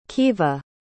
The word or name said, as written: keeva